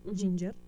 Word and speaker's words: Jinjer: Bueno, yo creo que así se pronuncia, pero es con J-I-N-J-E-R, Ginger.